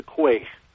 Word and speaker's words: Quaich: Tell us again, as you did before we came on the air, how you pronounce it and what it means. – Oh yes, I'm a member of the Keeper of the Quay, Q-U-A-I-C-H, and it's an honorary society devoted to Scotch whiskey, both single malts and blends.